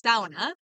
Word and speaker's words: sauna: When we opened our business, which is a mobile sauna, we learned that we had to start saying it the traditional Finnish way. The Finnish way, which is sauna.